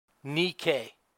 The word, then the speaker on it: Nike: Nikkei, what's that? Do you remember that? That's actually really good pronunciation of the Greek word that John overuses in these four verses. Victory. Nike. Where Nike gets its name.